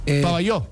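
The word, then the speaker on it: Pavajeau: En el Armando Maestre de Pabajau. – Pabayó. – Es Pabayú. – Sí, Pabayú. Sí, sí, se pronuncia Pabayó.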